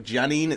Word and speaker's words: Giannine: It's like Janine. It's surprisingly similar to her real name. – It is the same name. It's just it's in the when you first meet her, her name is spelled like G-I-A-N-N-I-N-E, and then yep. It's a more simplified version in the game. – Oh, okay. This is my dyslexic brain. It's pronounced the same. It's just spelled differently.